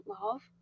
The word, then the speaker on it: Lauv: Lauv.